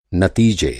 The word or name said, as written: नतीजा